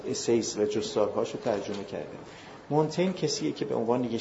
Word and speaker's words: مونتین: از متفکرانی که خواهشم اینه تا هفته آینده دربارش حتما بخونین، یکی از مهمترین متفکران آره مونتینیه در واقع تلفظ فرانسوی، میشل دو مونتین